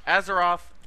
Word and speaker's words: Azeroth: Azeroth.